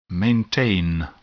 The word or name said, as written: maintain